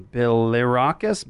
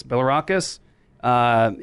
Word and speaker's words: Bilirakis: And just for the record, that was Representative Gus Bilar. Oh, I can't even say his name. It's a tough one. Bilirakis. Bilirakis.